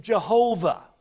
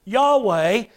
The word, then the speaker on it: Yahweh: And some still pronounce it Jehovah. But this is Yahweh.